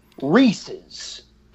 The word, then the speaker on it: Reese's: I think, and Bill, for the record, you said it right, and I got to give you so much. It is, for everybody out there, I really want you to hear this. It is Reese's.